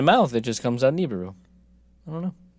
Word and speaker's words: Nibiru: Well, I mean, we're basically saying it the same way, except I'm putting the emphasis on a different syllable. I'm saying Nibiru, you're saying Nibiru.